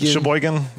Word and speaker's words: Sheboygan: I know that in my head, but like you're sounding it like Sheboygan. – Almost right. Sheboygan. – Sheboygan, I think that's it.